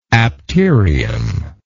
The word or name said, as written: apterial